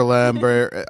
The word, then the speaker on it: Lambert: I looked up how do you pronounce Christopher Lambert. I'm pretty sure it's Lambert.